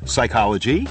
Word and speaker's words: psychology: Anytime you see a word that begins with PN. – Oh. – PS, psychology. – Right. – PH, philosophy. – Mm. – Okay, where the P is silent, well, yeah.